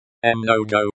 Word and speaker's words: Много: Mnogo, like the English word, mango.